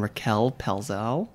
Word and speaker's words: Raquel Pelzel: We have Adeena Sussman and Raquel Pelzel.